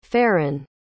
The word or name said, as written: feran